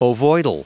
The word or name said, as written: ovoidal